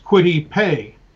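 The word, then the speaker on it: Kwity Paye: And his first name is K-W-I-T-Y. Last name is P-A-Y-E. I don't want to have to think about it. So it's pronounced Quitty Pay.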